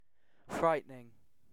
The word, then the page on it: frightening: British English